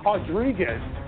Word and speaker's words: Rodriguez: She will fight Marina Rodriguez. We don't pronounce the R's in Brazil.